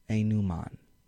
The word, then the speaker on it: denouement: Pronounced Enuman. I like that one.